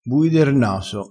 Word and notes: bui der naso: dialettale

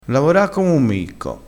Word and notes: micco: dialettale

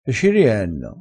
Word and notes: e ci rienno: dialettale

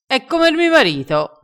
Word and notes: prende r sale: dialettale

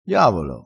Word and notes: diavolo: dialettale

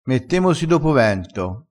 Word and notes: dopo vento: dialettale

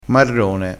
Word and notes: marrone: dialettale

